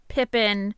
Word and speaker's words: Pepin: Sometimes Pepin, like he's pronounced Pepin, Pepin, like in French it would be Pepin, and sometimes it's Pepin, sometimes it's Pippin. I like Pippin.